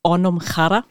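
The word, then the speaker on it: Anam Cara: Richtig, ja, also ganz korrekt ausgesprochen ist es Anamkara.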